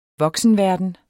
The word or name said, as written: voksenverden